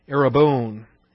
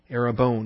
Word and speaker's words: arrabon: Arabone, A-R-R-A-B-O-N, that's the long O, the omega. Arabone, not Arabon, Arabone, number 728 in the Strong's Concordance.